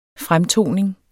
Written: fremtoning

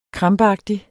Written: krampeagtig

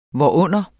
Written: hvorunder

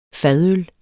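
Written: fadøl

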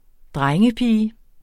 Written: drengepige

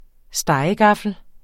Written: stegegaffel

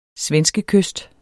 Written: svenskekyst

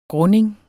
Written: grunding

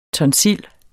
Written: tonsil

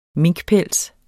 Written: minkpels

